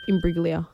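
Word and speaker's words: Imbruglia: Soft G. – Soft G. – Imbriglia.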